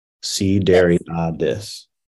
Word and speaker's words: Sideriadis: So C, like the ocean. Dairy, like milk. – C, dairy. – Ah, like A-H. And this. Sideriadis.